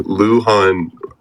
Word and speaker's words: Luhan: Luhan rhymes with Wuhan, China, which is where COVID-19 started.